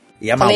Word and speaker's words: Yamaoka: Ah, é Yamaoka que fala? – Yamaoka. Falei errado.